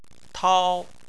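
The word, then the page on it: tao: tone 1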